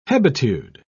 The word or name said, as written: hebetude